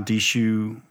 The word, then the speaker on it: Dhsu: There were some that I really loved that I did a couple with Dishu, is how Dishu is pronounced, D-H-S-U.